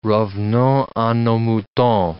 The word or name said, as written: revenons a nos moutons